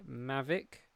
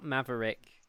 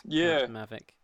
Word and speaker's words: Mavic: Not Maverick. It's not spelt Maverick. There's no E and R. It's just M-A-V-I-C, which I'm assuming is Mavic.